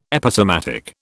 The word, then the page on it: Episematic: British English